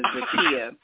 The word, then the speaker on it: Zakiya: Yeah, just really quickly, the correct pronunciation of my first name is Zakiya.